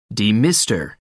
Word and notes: demister: American English

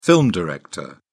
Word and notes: film director: British English

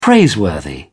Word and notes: praiseworthy: British English

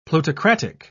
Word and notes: plutocratic: British English

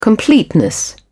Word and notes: completeness: British English

